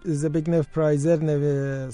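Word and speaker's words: Zbigniew Preisner: الان اشاره کردی به زبگنف پرایزر، سازنده موسیقی متن فیلم کیشلوفسکی.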